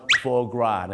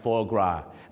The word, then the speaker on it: foie gras: เสียงว่า foie gras นะฮะ foie gras ไม่ใช่ฝอยกราสนะ